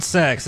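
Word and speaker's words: CEX: I think it's pronounced sex. But it's C-E-X.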